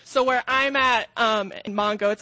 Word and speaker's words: Mango: And I'm up in the northern part in a town called Mongo, spelled like mango, pronounced Mongo.